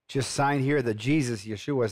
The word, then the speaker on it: Yeshua: What happened to you when you found, if I can say this right, Yeshua? – Yeshua, Jesus.